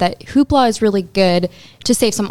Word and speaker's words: Hoopla: And I want to kick it off with my personal favorite, Hoopla, right? Is that how you say it? Hoopla. – Yes. Hoopla.